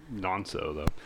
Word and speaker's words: Nonso: Also because you might have pronounced it wrong. – I probably did. It is, it's Nonso, though.